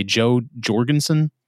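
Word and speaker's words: Jo Jorgensen: Are they Joe Jorgensen? – That sounds right.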